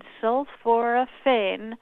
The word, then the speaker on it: sulforaphane: And this compound, sulforaphane, four syllables, good to bring it up at a boring party to ask people what they yes.